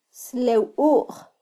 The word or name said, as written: slewuj